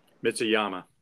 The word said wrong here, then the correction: Matsuyama: Yeah, Matsuyama, Adam Scott, and Sungjae Im are probably the most popular or well-known internationals.